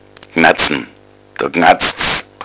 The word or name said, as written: gnatzen